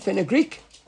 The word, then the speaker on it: fenugreek: Is fenugreek with a silent K at the end? By the way, you pronounce it with a K at the end. Fenugreek.